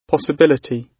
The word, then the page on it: possibility: British English